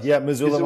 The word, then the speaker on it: Missoula: And did I pronounce it? Missoula. Missoula, Montana. Yeah. You got it.